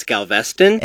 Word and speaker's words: Galveston: Was always so funny when we had the partnership with the Weather Channel, and they would call in, and I'd have to tell them, no, okay, it's Galveston.